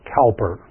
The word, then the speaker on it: Cowper: We pronounce that Cowper in this part of the world, but he was a great friend of John Newton.